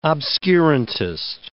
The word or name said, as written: obscurantist